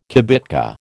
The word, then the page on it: kibitka: American English